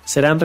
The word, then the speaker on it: CERHAN: Serán, recuerden que se escribe C-E-R-H-A-N y estamos ubicados en el mapa en la dirección kilómetro 9.3, autopista Audi San José, Chiapa, Puebla, Puebla.